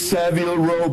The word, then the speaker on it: Savile Row: How do you pronounce it properly? – It's Savile Row, baby.